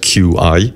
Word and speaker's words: Qi: And when I say Qi, it's spelled Q-I, pronounced Qi.